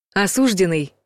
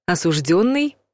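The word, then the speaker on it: осуждённый: Осужденный — неправильный вариант. Осужденный — правильный вариант.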